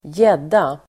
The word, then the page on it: gädda: [²j'ed:a]